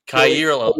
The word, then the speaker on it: Kaiir Elam: Yeah, you know how to pronounce Kaiir Elam.